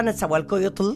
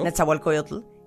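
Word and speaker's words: Nezahualcóyotl: Literal quiero decirles que el maestro conductor, Carlos Prieto, está en la sala Netzahualcoyotl, lo dije bien. Perfecto. Netzahualcoyotl, interrumpió el ensayo para hablar con nosotros.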